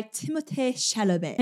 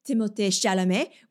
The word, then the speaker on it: Timothée Chalamet: Like, do I call him Timothée Chalamet, which is his name, but since I'm speaking English, makes me sound like a douche? Or do I just call him Timothée Chalamet and inevitably have people in the comments being like, well, actually, that's not how you're supposed to pronounce it.